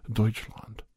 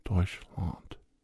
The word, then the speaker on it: Deutschland: Bavaria is a proud, proud area of Deutschland. That's how you say it in German, Deutschland.